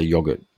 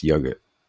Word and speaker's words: yogurt: I would say ban the improper use of the pronunciation of yogurt. – There you go. One for Deputy PM. Mm-hmm. – You just said yogurt. – Yeah, you can't say yogurt. It's yogurt.